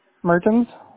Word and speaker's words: Mertens: Mertens. – Could you spell that? – M-E-R-T-E-N-S.